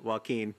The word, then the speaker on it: Joaquin: Joaquin. – Not Jokinawa or whatever you said. – Did you just pronounce it? I think I said Joe Quinn.